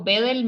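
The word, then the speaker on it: Wedel: En Instagram me pueden encontrar como doctora Karen, rayita abajo, Bedel, mi apellido se escribe con W, W-E-D-E-L. Así aparezco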